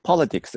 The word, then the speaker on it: Politics: で、アクセントですね。 Politics です。